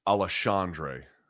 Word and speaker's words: Alexandre: How do you pronounce his first name correctly? – As I understand it, it's Alexandre.